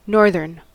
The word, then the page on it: northern: American English